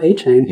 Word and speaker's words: Achane: And then uh, Aw Shane, all Chain's another one, but it's just straight up Achane. – Yeah, it sounds, it's much easier for us to pronounce as Achane. So yeah, glad that's what it is, just like Chappelle's show.